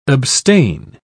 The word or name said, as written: abstain